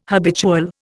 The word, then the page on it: habitual: Indian